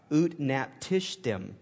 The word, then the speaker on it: Utnapishtim: It's called Utnapishtim.